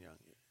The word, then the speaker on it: Young: I don't get Kevin Young. His name looks like Jung, but he pronounces it Young.